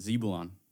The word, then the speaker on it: Zebulun: Zebulun. That's how you say it. – You think so? – How do you say it? – Well, I would say Zebulun. – No, the U is more prominent. Zebulun.